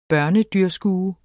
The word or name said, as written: børnedyrskue